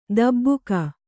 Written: ಧಬಕ

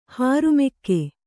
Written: ಹೋರಿಮಿಗ

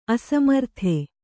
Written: ಅಸಮರ್ಥ

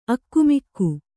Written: ಆಗಾಮಿಕ

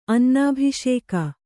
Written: ಅನ್ನಾಭಿಷೇಕ

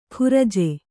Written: ಖುರಜೆ

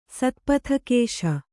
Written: ಸತ್ಪಥಕೇಶ

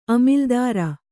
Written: ಅಮಿಲ್ದಾರ

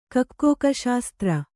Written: ಕೊಕ್ಕೋಕಶಾಸ್ತ್ರ

